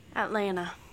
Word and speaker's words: Atlanta: You don't pronounce the T. It's fine. It's cool. – Atlanta.